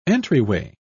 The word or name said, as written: entryway